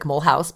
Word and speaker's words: Mulhouse: Mulhouse, M-U-L-H-O-U-S-E. It looks like Mulhouse, but it's pronounced Mulhouse.